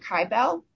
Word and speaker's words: Kibel: I pronounced it Kibel. You know, it's K-I-B-E-L. – Oh, K-I-B-E-L. Okay, I had spelled it C-A-R-V-E-L, and that wasn't the case I thought I was familiar with.